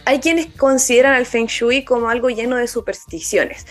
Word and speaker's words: Feng Shui: Dato curioso, en chino Feng Shui se pronuncia Feng Shui.